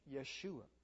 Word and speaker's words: Yeshua: He took the divine name Yahweh and combined it with the boy's name Hoshea, and it's pronounced Yeshua.